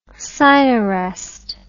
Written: Sinarest

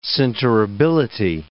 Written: sinterability

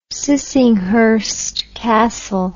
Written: Sissinghurst Castle

